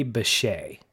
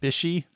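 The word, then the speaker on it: Bishé: Her name is pronounced Carrie Bechet. Okay. Carrie Bechet. If you're listening, we apologize profusely for butchering your last name. – I think I said Bishy. Yeah. – Bishy, Bijou, Bijois. No, it's Bechet.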